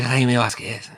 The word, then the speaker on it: Jaime Jaquez: Tu le prononces comment, excuse-moi? – Jaime Jaquez. Mais vas-y, j'ai pas l'accent. C'est comment que tu prononces toi l'accent là, vas-y balance. – Et Jaime Jaquez.